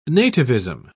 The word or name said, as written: nativism